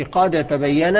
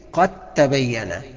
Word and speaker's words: قد تبين: قد تبين، ما تقولش قد تبين، انما النطق الصحيح قد تبين، القاف مع التاء على طول. سيبك من الدال دي، حط الدال في التاء وقولهم كلمه واحده قد تبين.